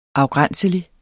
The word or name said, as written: afgrænselig